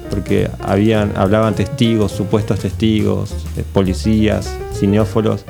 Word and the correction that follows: cinéfilos: creo que se dice así, no sé, cineastas. Cinéfilos. – Cinéfilos.